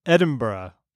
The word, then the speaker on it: Edinburgh: It's pronounced Edinburgh.